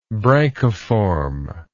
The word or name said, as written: branchiform